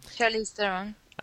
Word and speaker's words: Charlize Theron: Dan Radcliffe, Charlize, is that how you pronounce her name? – Charlize Theron.